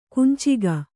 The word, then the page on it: ಕುಂಚಿಗ: kunciga